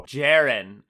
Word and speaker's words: Jaron: I don't know how to pronounce his first name either. I think it's like Jaron. – Okay, well, look up the pronunciation. – He's out of Louisville, and he was overdrafted. I can tell you that much. – I need to know if I'm right, because I'm getting a lot of flack lately from the listeners about me aggressively mispronouncing names. So Jaron. I was close.